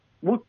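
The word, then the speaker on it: Bout: Trong cái cuộc trao đổi này, mà như anh Hoàng Tín đọc vừa rồi là Victor Bao, tôi không, đó là cách đọc theo người Mỹ. Còn nếu mà đọc theo kiểu người Nga, thì chúng ta phải đọc là Victor Bút.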